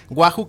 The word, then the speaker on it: Wahu: Wahoo, que se escribe W-A-H-U, viene de We Are Humans en inglés, que es Todos Somos Humanos.